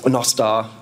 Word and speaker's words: Nostr: Nostar, as it should be pronounced,